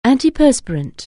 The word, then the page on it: antiperspirant: anglais